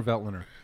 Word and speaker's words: Veltliner: Organic Gruner Ventliner. Am I saying that right? I don't think I'm saying that right. – Gruner Veltliner. – Veltliner. – But Veltliner works.